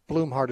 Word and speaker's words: Blumhardt: Blumhardt is spelled B-L-U-M-H-A-R-D-T, Blumhardt.